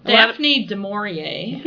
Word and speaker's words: du Maurier: Daphne du Maurier.